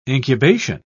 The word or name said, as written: incubation